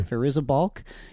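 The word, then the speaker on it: Fairuza Balk: Is that how you say her name? – Sure. – Firuza Balk as Dorothy.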